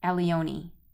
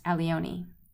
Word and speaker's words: Allione: It's by a Buddhist nun by the name of, and I can't pronounce her first name, but her last name is spelled A-L-L-I-O-N-E, Elione. I think she married an Italian man, and I think it's pronounced Elione. Great book, Feed Your Demons.